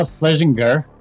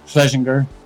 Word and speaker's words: Schlezinger: Today is a very special guest, as always, is Gal Schlezinger. I think I said that right. Coming also from near Tel Aviv. How you doing, Gal? – Yeah, I'm good. It was almost correct. It was Schlezinger, but that's fine.